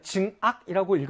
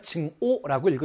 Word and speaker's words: 증오: 그러면 증악이라고 읽으셔야 되나요? 증오라고 읽으셔야 되나요? 아, 뒤에는 오자도 미워하다의 뜻을 가진 오자가 되는구나. 따라서 증악이 아니라 증오로 읽어야 되는구나.